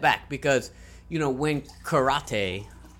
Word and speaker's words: karate: First of all, it's karate.